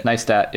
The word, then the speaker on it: Neistat: Neistat, yeah.